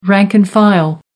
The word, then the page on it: rank and file: American English